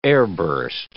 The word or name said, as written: airburst